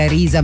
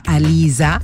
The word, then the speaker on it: Arisa: Non dicevano proprio esattamente Arisa ma Alisa, la R sapete che fanno fatica,